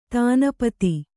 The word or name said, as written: ದಿನಪತಿ